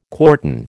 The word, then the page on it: quartan: American English